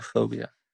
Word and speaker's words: oenophobia: Unophobia. Which is spelled O-E-N-O-P-H-O-B-I-A.